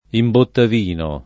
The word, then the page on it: imbottavino: italiana